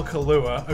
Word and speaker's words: Kaluuya: laughs> Get Daniel Kaluuya, according to Parker. That's how you say his name.